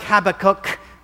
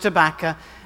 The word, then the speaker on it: Habakkuk: Originally, the plan was to read Habakkuk, and again, it looks like Habakkuk, but it's pronounced Habakkuk, and also Obadiah.